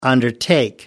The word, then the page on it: undertake: American English